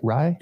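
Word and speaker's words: Rai: That's how you say it, right? Rye? – Yep.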